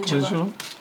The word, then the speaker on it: Calendula: Calendula. Did I say that right? – Calendula.